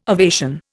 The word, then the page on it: ovation: Indian